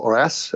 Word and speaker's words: Reims: One is called Reims or Arras, as the French pronounce it, or Epinay.